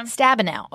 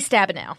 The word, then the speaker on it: Stabenow: Stabenow. – Okay. Good. Debbie Stabenow.